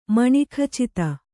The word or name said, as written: ಮಣಿಖಚಿತ